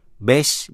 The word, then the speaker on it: bass: Bass, B-A-S-S, phát âm là bass.